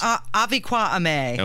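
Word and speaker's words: Avi Kwa Ame: Would you like to just, again, let everybody know what the actual pronunciation is? – Aviqua Ame. – Okay, very good.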